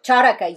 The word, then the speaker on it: Charaka: So this comes from Charaka. – Charaka, yes. – Did I pronounce that right? – Yes.